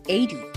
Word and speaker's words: Aidy: Aidy, how do you say this? – Aidy Bryant? – Aidy.